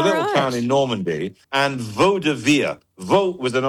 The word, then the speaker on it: Vaudeville: I've never been sure if it's Vaudeville or Vaudeville. – Vaudeville is the correct pronunciation.